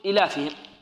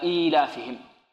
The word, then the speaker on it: إيلافهم: خافوا أن يقرأها القارئ يقول إيلافهم، فهنا جاءوا بيا صغيرة، صغيرة الحجم، وضعوها في هذه الكلمة كي ينطقها ماذا؟ أو كي ينطقها قارئ القرآن. إذن ننطق هكذا إيلافهم، لإيلاف قريش إيلافهم.